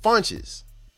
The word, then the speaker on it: Fontes: Now, he pronounces his name Fonts. He changed it in high school, according to his brother, because it sounded cooler. They say Fontes, but the Portuguese woman said that it's actually, he wrote his name, his last name on a piece of paper, showed it to the woman and said, "Ma'am, how do you pronounce this name?" And it's pronounced Funches.